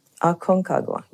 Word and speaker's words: Aconcagua: And South America, Mount Aconcagua. Can you pronounce it for me? – Aconcagua, right.